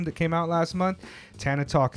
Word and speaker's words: Tana Talk: Yeah, like Tony Montana. Tony Montana. – Word. – Yeah, I think that's how it's pronounced. Tana Talk.